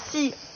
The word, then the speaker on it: tsy: Donc, « Si » T-S-Y, entre parenthèses, plus loin, possible. – Oui, alors si, c'est la négation en malgache